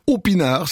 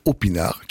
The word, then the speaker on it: Aupinard: Il s'appelle Opinard, si vous le cherchez, je vous le rappelle, ça s'écrit en un seul mot, A-U-P-I-N-A-R-D, Opinard, qui nous propose un thé pour cette découverte.